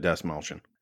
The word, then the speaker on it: Dastmalchian: And I finally figured out how to say his name without saying it in my head first. – Yeah, you focused on the mal part, and you just Dastmalchian for a long time.